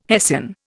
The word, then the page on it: hessian: Indian